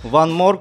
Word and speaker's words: Von Morgue: Алена с телеграм-канала, с YouTube-канала One More, правильно сказал? – Правильно сказал.